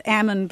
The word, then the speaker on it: almond: And yeah, what they say is that they pronounce it almond because, you know, to get the almonds out of a tree, you have to shake the tree, and they say they've had the L shaken out.